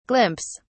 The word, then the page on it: glimpse: British English